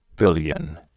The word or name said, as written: billion